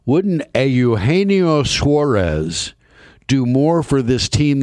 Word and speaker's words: Eugenio Suarez: Eugenio Suarez. That's the correct pronunciation of that name.